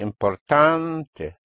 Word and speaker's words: importante: Important, therefore, will be importante. So ante and ente. Ante and ente. With the stress on the an or en.